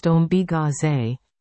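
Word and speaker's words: Ombiigizi: Ambigeezy. Pronounced Ombigaze, meaning this is noisy, is a collaboration between Zoom, Daniel Monkman, and Status, Non-Status, Adam Sturgeon, Anishinaabe artists who explore their cultural histories through sound.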